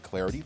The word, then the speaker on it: Clardy: Number one, you can follow me on Twitter at Troy Clarity. The last name is spelled C-L-A-R-D-Y, at Troy Clarity.